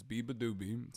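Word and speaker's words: Beabadoobee: Her name is Biba Doobie. So Biba Doobie opened for her, for Taylor, as well as Gracie Abrams.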